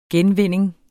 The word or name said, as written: genvinding